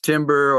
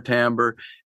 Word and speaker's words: timbre: And timbre, sometimes it's pronounced timber or timbre, and both are acceptable.